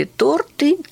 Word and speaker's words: торты: Часто ели торты, тесны стали шорты. Все, ударение на место встало.